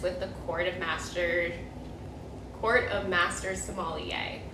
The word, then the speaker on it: sommelier: So then I actually didn't even know this word before we did this, but sommelier, is that how you say it? – Yeah, sommelier.